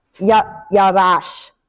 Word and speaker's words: yarash: The word is yaras. Not I'm probably saying that wrong. Yarash. That's how yarash, not your yarash.